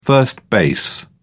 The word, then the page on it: first base: British English